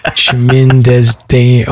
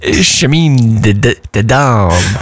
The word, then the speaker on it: Chemin des Dames: Chemin des Dames. Oh my God. I'm sorry. The listener who taught me how to say it, I've completely forgotten. Chemin des Dames. – Chemin de Dame.